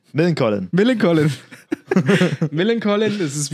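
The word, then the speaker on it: Millencolin: Millen Collin. Millen Collin, klingt wie so ein Doppelname aus Millen und Collin.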